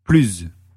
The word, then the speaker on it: plus: Mais quand l'adjectif suivant commence par une voyelle ou un H, on prononce plus.